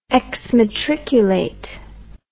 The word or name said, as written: exmatriculate